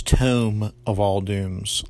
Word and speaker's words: Tome of All Dooms: Quick correction, I called Jeff's podcast The Tomb of All Dooms last episode. It's Tome of All Dooms, like a book. I knew that when I was saying it, I just mispronounced it.